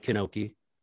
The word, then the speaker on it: Knoche: And so you guys can, everyone out there can find me on Hammer at Mike Kanoche. My last name is spelled K-N-O-C-H-E on the Hammer app.